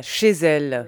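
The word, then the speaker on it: Chez Zelle: Ça s'appelle comme ça, chez elle. Oui. Mais qu'est-ce que c'est chez elle ? – Alors, chez elle, donc ça s'écrit aussi chez, comme chez tout le monde. Et puis Zelle, Z-E-L-L-E.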